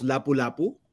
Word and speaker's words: Lapu-Lapu: Can you tell us about Lapu-Lapu? Yeah, I'm probably not pronouncing that correctly. I apologize. – No, don't worry. That is a Cebuano name. It's actually pronounced Lapu-Lapu.